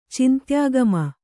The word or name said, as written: ಚಿಂತ್ಯಾಗಮ